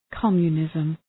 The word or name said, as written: communism